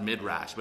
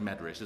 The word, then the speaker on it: Midrash: I'm reading out of the Medrash, which is it looks like it's pronounced Midrash, but it's like Medrash.